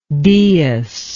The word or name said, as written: deus